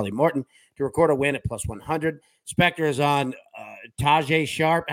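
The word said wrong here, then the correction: Tadej Pogačar: How do you pronounce his name? – Tade Pogacar.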